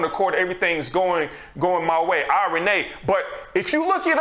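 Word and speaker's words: irene (εἰρήνη): But I looked this up in the Greek, and it's a word, irene, and that's how you pronounce it, is irene.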